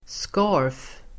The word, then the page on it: scarf: [ska:f]